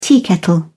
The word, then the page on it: teakettle: British English